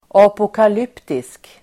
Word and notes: apokalyptisk: [apokal'yp:tisk]

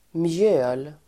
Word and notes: mjöl: [mjö:l]

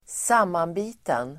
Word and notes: sammanbiten: [²s'am:anbi:ten]

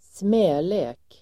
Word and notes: smälek: [sm'ä:le:k]

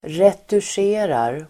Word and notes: retuscherar: [retusj'e:rar]

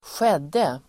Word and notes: skedde: [²sj'ed:e]

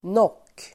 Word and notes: nock: nåk:

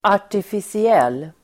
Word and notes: artificiell: [ar_tifisi'el:]

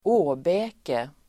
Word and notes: åbäke: [²'å:bä:ke]

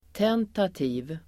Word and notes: tentativ: [t'en:tati:v el. -t'i:v]

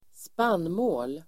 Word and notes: spannmål: [²sp'an:må:l]

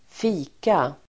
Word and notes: fika: [²f'i:ka]

